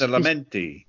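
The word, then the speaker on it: Badalamenti: That is Tim Booth or an Angelo, and then I can never pronounce it. Adelamenti. – That's it. Well done. Over there has managed to pronounce it correctly.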